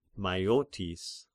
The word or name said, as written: Maiotis